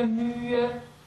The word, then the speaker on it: mühe: Now, H's close a vowel, but do you pronounce them when they're between two vowels, like frühe and mühe? – You don't. – No? – You do it just like you said, frühe, mühe. It's legato, and you don't say frühe, mühe.